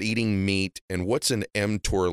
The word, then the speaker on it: mTOR: Yeah, you're pronouncing it correctly. It's called mTOR.